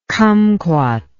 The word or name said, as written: cumquat